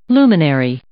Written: luminary

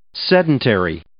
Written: sedentary

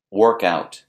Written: work out

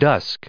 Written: dusk

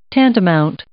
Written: tantamount

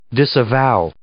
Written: disavow

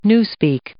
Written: newspeak

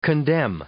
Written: condemn

